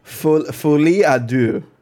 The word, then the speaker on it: Folie à deux: Folie à deux.